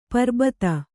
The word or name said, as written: ಪರಿಭೇದ